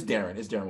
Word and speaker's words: Deron: Is that Deron Williams or is it Darren? – It's Darren Williams. Yeah. But it's spelled Deron.